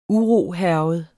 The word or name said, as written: urohærget